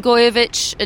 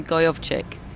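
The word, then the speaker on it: Gojowczyk: I was saying Gojovic until Sunday, and then I got corrected by a German to Gojovic.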